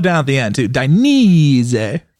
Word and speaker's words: Dainese: Dynes. – Dine. Ease. No. – Die. – Die. – Knee. – Knee. Dainese. – Zay. – Dainese.